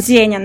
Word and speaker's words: Зеня: Слушай, я у тебя вчера на стене увидела, что э, команда представила новый стартап э, Йога Ассистент Зенья, Зинья, не знаю, как правильно он читается. – Э, Зеня называется. – Зеня? Да. А, прикольно.